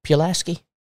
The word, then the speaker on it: Pulaski: And the associate minister for the East Hill Church of Christ in Pulaski, Tennessee. Did I say that right? – Pulaski.